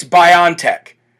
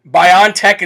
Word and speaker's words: BioNTech: Earlier, I kind of broke down the best I could for everybody what the difference was between the Pfizer approval, and here's the proper way to say this, by the way. I found out today after doing research for about 2.5 hours on this company, it's not BioNTech, it's not BioNTech, it's BioNTech. BioNTech is the proper way to say this company's name.